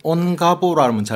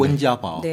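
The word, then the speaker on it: 원자바오 (温家宝): 온가보라면 잘 모르시게 중국 발음으로 네. – 원자바오.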